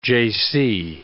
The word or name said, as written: Jaycee